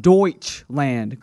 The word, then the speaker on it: Deutschland: And for the last time, Greg, it's pronounced Deutschland, Greg. Okay. You keep saying something else.